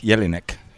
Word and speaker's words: Jelinek: Have I pronounced it right? – Correct. Jelinek. Jelinek.